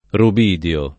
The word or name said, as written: rubidio